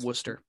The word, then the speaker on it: Worcester: Isn't it Worcester? – I don't care. – I think it's pronounced Worcester, but all right.